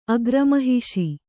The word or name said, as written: ಅಗ್ರಮಹಿಷಿ